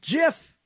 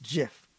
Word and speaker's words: GIF: So we called up CompuServe, and we asked Mr. Wilhite and the guys who had invented it, how do you pronounce it? Said GIF. That settles it. Still, there are idiots who want to say every G has to now be pronounced as a hard G, which means when you do that, you make a gigantic error. And so we called them up. We found out, and it's pronounced GIF.